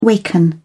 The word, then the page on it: waken: British English